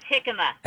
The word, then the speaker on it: jicama: So it's pronounced Jicama. – Jicama.